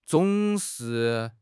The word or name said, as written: zong3 shi4